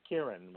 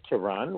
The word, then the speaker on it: Kiran: You said Kiran, which is the proper way to say it. But some Kiran. – People say Kiran, which is also okay. I respond to both.